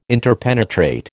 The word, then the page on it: interpenetrate: American English